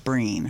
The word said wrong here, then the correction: Brené: Her name is B-R-E. – Brené Brown. – Brené. Mm-hmm. It's Brené. Brené Brown.